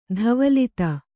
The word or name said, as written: ಧವಲಿತ